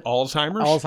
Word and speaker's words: Alzheimer's: Alzheimer's? – Alzheimer's, yeah.